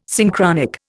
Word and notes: Synchronic: Indian

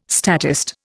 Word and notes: statist: Indian

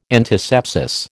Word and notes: antisepsis: American English

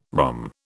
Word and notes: ROM: British English

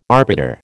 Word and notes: arbiter: American English

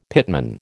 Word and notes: pitman: American English

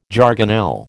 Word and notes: jargonelle: American English

